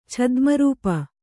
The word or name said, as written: ಛದ್ಮರೂಪ